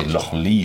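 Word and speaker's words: Lochlea: Lochlear ist eine neue Brennerei in den Lowlands. Eigentlich heißen sie Lochlee, spricht man es aus.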